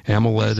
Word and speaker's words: AMOLED: It's the R382 AMOLED, however we say that, Marcia. AMOLED. – You said it right.